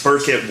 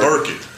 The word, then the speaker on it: Byrkit: It's Burkett Ward. – Burkett. And it's spelled B-Y-R-K-I-T, so that's why I had to add.